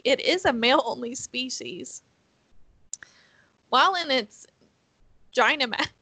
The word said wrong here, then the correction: Gigantamax: Gigantamax. Gynamax. – Vaginamax, yeah. – Okay, say it again so I can say it right. – Gigantamax.